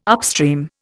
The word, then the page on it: upstream: Indian